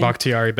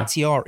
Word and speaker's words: Bakhtiari: Bakhtiari back. Yeah. – Bakhtiari.